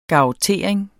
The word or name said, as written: garrottering